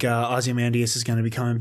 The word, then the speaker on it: Ozymandias: I say Ozymandias. – Ozymandias. – I've said it wrong my whole life.